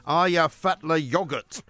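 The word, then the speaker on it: Eyjafjallajökull: Ayafatla yogurt. Uh, apparently, that's how you say it in Icelandic. Anyway, it doesn't spell like that.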